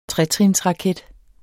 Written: tretrinsraket

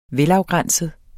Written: MODNET

